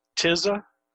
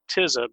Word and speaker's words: Tisza: The first one is, I'm not sure on the pronunciation. I'm going to go with Tisa. Ossenfelder wrote it as T-H-E-Y-S-E. But I think it's a river that runs through Central Europe, and I think it's pronounced Tisza because an alternate spelling is T-I-S-Z-A. So I'm going with the Tisza pronunciation.